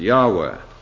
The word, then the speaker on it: Yahweh: But the word is Jehovah, or as some think it should be pronounced, Yahweh.